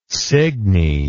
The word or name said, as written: Signy